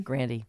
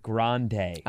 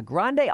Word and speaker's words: Grande: Be, what's the other pronunciation? – Grandy. – That doesn't work at all. – Mm-mm. – So she needs to stick with Grande. – Oh, yeah. Grande all the way.